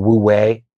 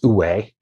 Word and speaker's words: wu wei: You know, and you read the Tao Te Ching and there's something called Wu Wei, or actually it's pronounced U Wei. It's spelled Wu Wei, but it's U Wei, which is effortless action.